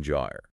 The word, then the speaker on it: gyre: It all started with a Central American gyre. Right. J-I-R-E, pronounced like tire, but with a J sound.